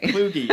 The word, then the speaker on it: Kluge: It's Kluge with a hard G and a long E, but that's okay. – Kluge.